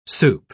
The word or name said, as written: soup